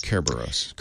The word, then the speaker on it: Cerberus: it complements the work Microsoft's been doing with the Open Compute Project around Project Cerberus. Is that how you say that? C-E-R-B-E-R-U-S? – Kerberos. Kerberos. – Kerberos. – Oh, Kerberos. I think I said this wrong the last time.